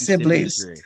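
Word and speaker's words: Sibblies: How do you pronounce it? Is it Sibley's Drury? Jackie Sibley's? – Yes, Sibley's.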